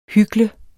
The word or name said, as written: hykler